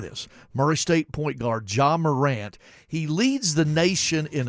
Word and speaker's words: Ja Morant: It's J.A. Morant, and he pronounced it Ja Morant, and he's, like I said, a lottery pick in the NBA draft.